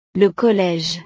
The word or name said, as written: le college